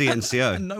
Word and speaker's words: CNCO: And when I called it Thinko. – You were pronouncing it, trying to do the authentic sort of accent on it, but going, that's Little Mix there, it's and Senko. – It's just CNCO.